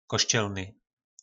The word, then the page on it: koscelny: po našimu